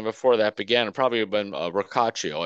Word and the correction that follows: Rocchio: I hope I'm saying that right. Rocaccio, who played at Double A. – Is it Rocchio? It's Rocchio, right? – Rocchio. I'm sorry. Yeah, Rocchio. Okay.